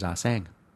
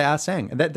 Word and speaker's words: Assange: and I think the name is Arsang. That's why I say Arsang. I'm sure I read an article somewhere that said that. Everyone else in the world says Arsang.